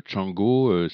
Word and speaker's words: Txango: Et on retrouve le groupe qui s'appelle Txango, c'est T-X-A-N-G-O. Normalement, le X, on le prononce che, il me semble.